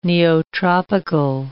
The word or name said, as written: neotropical